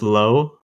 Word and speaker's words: Loewe: I think it's pronounced low. Low.